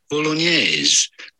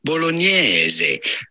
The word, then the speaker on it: bolognese: Of course, in English or American, you normally say it bolognese. Bolognese, but the typical Italian pronunciation is quite different. In fact, in Italian, they say bolognese.